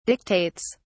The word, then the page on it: dictates: British English